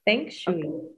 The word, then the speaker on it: Feng Shui: Sí, Feng Shui.